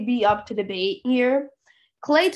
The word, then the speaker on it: Klay: His name rhymes with hay. – Well, no, you're wrong. It actually is Thompson, yeah. I'm joking. Yeah, Klay Thompson is one of the largest snubs, in my opinion, from this top 75 list.